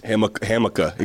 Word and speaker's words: Hamilcar: How cool of a name is Hamilcar? – Hamica. It goes Hamica, but it's spelled Hamilcar. It looks like Hamilcar Rashed. But it's Hamica Rashid.